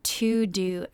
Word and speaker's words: Teuxdeux: So it is spelled T-E-U-X-D-E-U-X. So it's pronounced like it would be in French, which would be To-Do, but this does not mean To-Do in French, just so we're clear.